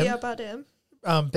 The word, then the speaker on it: Javier Bardem: Javier, however you say his name, Bardem. – Javier Bardem.